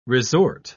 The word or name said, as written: resort